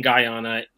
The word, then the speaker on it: Guyana: Well, it definitely Guyana. Is Guyana. And it's spelled the same way it's pronounced. If you break it up, Guyana.